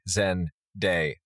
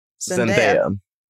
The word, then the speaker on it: Zendaya: It is in fact not like papaya, but like day. Zendaya. – Zendaya.